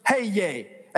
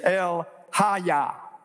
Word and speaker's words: El Haya: When I first saw it in print, I thought it's just like it looks. Hey, yay. H-A-Y-Y-A-Y. Hey, yay. Well, that seems like a good name to call God. Well, that's not the way you'd pronounce it. It's El-Haya.